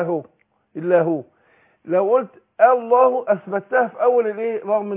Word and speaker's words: الله: لكن في وسط الكلام هتسقط. اقول فضل الله. يبقى نطقت اللامين ورا بعض والهمزه في النص ايه تسقط. – يبقى انا هنطق اللامين ورا بعض. اللام الاولانيه مرققه اللي هي تفضل واللام لفظ الجلاله هتفخم عشان اللي هي اللام اللي قبلها ايه مفتوحه.